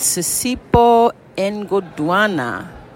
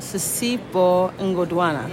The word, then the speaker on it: Sisipho Ngodwana: Doreen Young with Audio Percolator with Sisipo Ngoduana. – Did I say that right? – Yes, except you added an extra N. – Oh. – So it's just Ngoduana. – Oh, Sisipo Ngoduana.